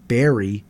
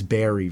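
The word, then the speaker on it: Barre: So since graduating in 2024, one of the graduates, obviously, Patty Merriam of Barry, which is actually spelled B-A-R-R-E. I was told recently it's not Bar, Vermont. It's Barry, Vermont. – Is it really? – Yes. – Oh, shoot. – That's how it's pronounced.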